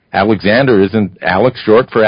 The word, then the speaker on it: Alec: But some people do pronounce it as if it had an X on the end. Right. In very careful pronunciation, it would be Alec.